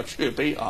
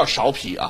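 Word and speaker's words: 芍陂: 淮南现在叫安丰堂啊，它过去的名字叫雀碑啊，不叫苕皮啊，古语发音啊叫雀碑啊。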